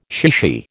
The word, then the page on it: chichi: American English